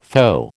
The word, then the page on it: FO: American English